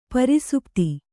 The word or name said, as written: ಪ್ರಸುಪ್ತ